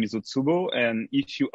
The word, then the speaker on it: Utsubo: the website that I'm talking about is Utsubo. Am I saying that right? – Yeah, you're right. It's all tubo.